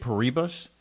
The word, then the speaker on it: Paribas: How do you say it, Paribas? – Yes. – Paribas? – I gotta say BNP. – It is the, what's it called? I should know this. At the Indian Wells Tennis Garden, the Paribas Open, Indian Wells Tennis Tournament.